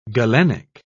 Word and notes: galenic: British English

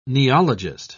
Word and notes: neologist: British English

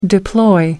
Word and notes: deploy: American English

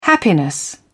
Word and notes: happiness: British English

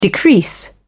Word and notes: decrease: British English

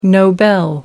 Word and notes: nobel: British English

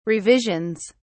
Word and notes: revisions: British English

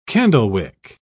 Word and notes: candlewick: British English

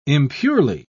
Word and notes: impurely: British English

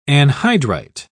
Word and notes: anhydrite: British English

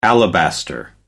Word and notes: alabaster: American English

